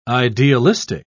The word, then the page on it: idealistic: British English